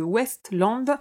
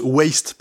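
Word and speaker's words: Waste Land: Alors par contre, juste, il faut prononcer un peu plus Waste, parce que Westland, c'est les terres de l'Ouest.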